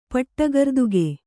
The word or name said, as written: ಪಟ್ಟಗರ್ದುಗೆ